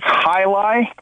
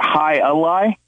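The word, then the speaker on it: Hialeah: It's high lie, not high a lie, not to be picky, but that is how, you know, the cultures pronounce it in Florida that brought it to Florida where I'm originally from.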